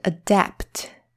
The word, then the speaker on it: adapt: 好，那再来呢，有一个跟它很像的字，把中间的 a 改成 o。发音会变成 adopt，adopt。刚刚那个是 adapt，这个是 adopt。